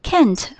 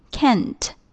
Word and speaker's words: can't: can't 和 can。发音前半部分是一样的，只是 can't 结尾多了一个轻轻的 t 这个音 can't，所以你要表达你会或者不会的时候，这个发音一定要注意 can't 和 can。